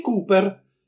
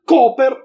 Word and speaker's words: Cooper: Dante, il rappresentante, litigò col geometra sui nomi in inglese, soprattutto sul fatto se Gary Cooper si pronuncia Cooper o Cooper. Ignorante, diceva il geometra, non lo sai che la doppia O si pronuncia U? Ah sì, rispose Dante. E tu come dici? Cooperativa o cooperativa?